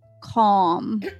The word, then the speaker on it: calm: One, it could mean that it has a short O sound. Example would be palm. See, it's low, palm, talk And again, your mouth is rounded out a little more like an O. Calm.